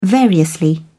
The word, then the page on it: variously: British English